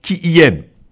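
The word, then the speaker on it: qiyyum: Ce terme se prononce « qui y aime ». Un petit peu compliqué à prononcer, mais c'est ainsi.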